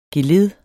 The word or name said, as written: geled